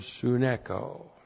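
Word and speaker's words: suneko (συνέχω): And then the verb suneko. S-U-N-E-C-H, long O, suneko.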